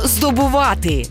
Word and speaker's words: здобувати: Сьогодні передача про слово «здобувати». І наголос в цьому слові ми ставимо на останньому складі.